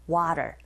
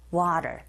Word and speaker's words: water: 네, 모음과 모음 사이에 t 사운드가 있으니 flap 현상에 의해서 water, water 이렇게 발음하시면 됩니다.